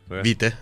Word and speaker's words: vitae: Vitae, es la pronunciación. CB, sí. Porque es latín.